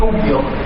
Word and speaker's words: cung dưỡng: Cúng là dường là thì thật đó là cung dưỡng. Phải đọc là chỉ cung dưỡng.